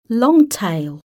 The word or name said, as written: long tail